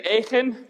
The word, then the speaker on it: Achan: And Achan, say Achan.